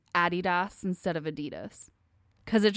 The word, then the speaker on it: Adidas: Adidas, as we call it in America, is the incorrect pronunciation, because it is actually Adidas.